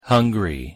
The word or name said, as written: hungry